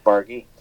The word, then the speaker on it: Bargy: It's a oh, hard G is it? Okay. – All right. Well, then there we go. Now our listeners know as well. Zach Bargy.